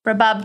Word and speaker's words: Rabab: Rabab did write that their name rhymes with kebab.